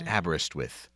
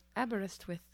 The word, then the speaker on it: Aberystwyth: This is Aberystwyth. – Aberystwyth.